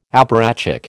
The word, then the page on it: apparatchik: American English